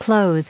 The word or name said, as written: clothe